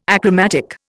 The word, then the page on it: achromatic: Indian